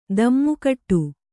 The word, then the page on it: ಧಿಮ್ಮಿಕಿಟ: Kannada